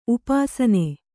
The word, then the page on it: ಉಪಾಸನೆ: upāsane; kannada